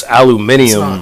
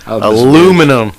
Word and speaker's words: aluminium: Yeah, it was aluminium. – Aluminium. Aluminum. Aluminium. – Whatever. They came up with that, right? But I love that. We like, nah, fuck y'all. It's aluminum. It's aluminum.